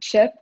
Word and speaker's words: CHIP: I am the CEO and founder of CHIP, which stands for Changing How Individuals Prosper, but it is pronounced just like potato chip.